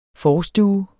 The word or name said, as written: forstue